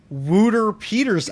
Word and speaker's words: Wouter Peeters: That is actually a sound clip from Burns Churches that he sent me on how to pronounce Wouter Peters' name. – Wouter Peters. – Wouter Peters. – Vote. Yeah, I like Wooter Peters better. – I know we have the typical Chicago / American pronunciation of that name. We fucking Wooter mangle penis. It. Yeah, we mangle the shit out of that thing.